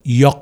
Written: Yawk

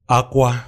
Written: AKWA

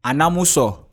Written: ANAMUSO

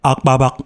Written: Akpabak

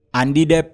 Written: ANDIDEP

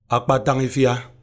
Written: Akpatangifia

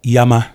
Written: Yama

